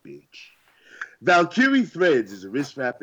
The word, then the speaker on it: Valkyrie: Stop. It's Valkyrie. – It's actually not. They actually tweeted us. – Yeah, they tweeted us and said we were saying it right.